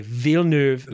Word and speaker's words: Villeneuve: So Villeneuve. We were like, okay, Villeneuve.